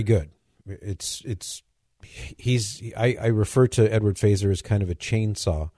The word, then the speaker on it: Feser: His last name is spelled F-E-S-E-R. It looks like Fesser, but he pronounces it Faeser.